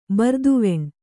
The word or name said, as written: ಬರ್ದುವೆಣ್